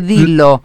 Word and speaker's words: Dillo: Decime, este Dilo que se escribe DILLO es. – Exacto, Ajá. – DILLO.